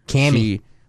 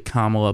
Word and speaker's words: Kamala: Cammy. – Which it's actually Kamala, but I'm going to keep saying it wrong because her.